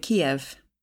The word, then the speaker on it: Kyiv: And then I remember that's why they chose this, because it's no longer K-I-E-V, it's K-Y-I-V. – Because quite early on, it came out how there's the Russian pronunciation and the Ukrainian pronunciation.